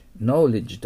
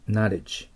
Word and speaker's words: knowledge: knowledge と読みません。 knowledge。